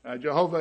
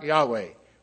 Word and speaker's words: Yahweh: Jehovah, it's been called, more likely something like Yahweh was the way that it would have been pronounced.